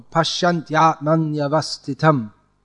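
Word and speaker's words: paśyanty ātmany avasthitam: But so it becomes confusing in that sense, but it's supposed to be said as one flow. Pashyantyatmanyavastitam. It's all one flow. There's no stopping in between. And there this ya before a vowel comes twice, which means if you pronounce it like Pashyanti Atmani Avastitam, you add two extra matras. It imbalances the whole meter of the verse.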